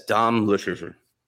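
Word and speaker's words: Dom Luszczyszyn: Like this, Dom Lucien.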